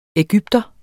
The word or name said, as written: EGYPTERE